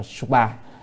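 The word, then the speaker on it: Subhā: Cái chữ này là chữ Sanskrit, chữ chữ chữ Sanskrit thì có chữ S U B là H A thêm dấu gạch ở trên là mình đọc, tạm đọc là Suba.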